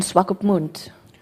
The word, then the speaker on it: Swakopmund: Okay, slowly. – Swakopmund. – Swakopmund. – That's good.